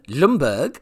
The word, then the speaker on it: Ljungby: It's going to be in Lundberg in Sweden. That's Ljungby. I'm pronouncing it the same as Freddie Lundberg used to play for Arsenal Football Club.